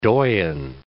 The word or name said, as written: doyen